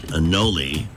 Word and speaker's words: anole: The Animal Rescue League there is concerned that this lizard, a brown anole, which rhymes with cannoli, take the gun, leave the anole, brought the lizard to the Brewster Animal Care and Adoption Center.